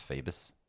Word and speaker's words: Phoebus: So I like to start them off a little documentary, and it's mentioned in this somewhere, I think, about the Phoebus cartel. – Okay. – I think it's Phoebus. – Spell it. – Phoebus. – Phoebus. – Okay, yep. Good.